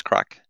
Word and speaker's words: craic: And we have a podcast called Serverless Crack, which is crack, that's spelled C-R-A-I-C, which is kind of an Irish word for kind of good fun.